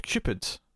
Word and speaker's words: Qpid: Oh, it's a Q episode because it's called Cupid. Notably spelt with just Q-P-I-D.